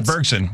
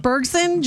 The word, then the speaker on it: Bergsen: Am I saying that right? – Yeah, Bergsen. Yep. – Bergsen, Germany, spending nearly 20 minutes browsing the aisles like they were shopping for bargains.